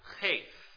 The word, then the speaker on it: Heth: It's the letter that's spelled H-E-T-H. And that H is really more of a kind of a hard C-H sound. So the letter is pronounced Chet, and it corresponds closely to our C-H sound in English.